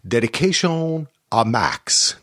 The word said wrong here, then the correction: Dedicado a Max: Did I pronounce that correctly? What do you think? – Dedicado a Max? – There you go. See, you did it better than I did.